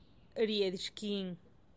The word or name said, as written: riedyxkin